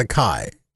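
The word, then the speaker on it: açaí: So how would you pronounce A-C-A-I? – Well, I know how people pronounce it, but it's really pronounced acai.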